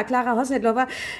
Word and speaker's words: Klára Hosnedlová: Ich finde das so toll, wie du das aussprechen kannst, diesen tschechischen Namen. Also ja, Klára Hosnedlová, ganz, ganz toll.